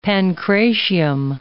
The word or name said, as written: pancratium